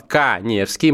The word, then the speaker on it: Каневский: Для этого вам нужно подписаться на мой инстаграм, Эдуард Каневский, моя фамилия пишется через А.